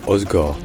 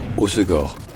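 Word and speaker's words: Hossegor: Néanmoins, si vous voulez vraiment être sûr de bien dire, dites ça comme là-bas, dites ça Osgor, en trois syllabes.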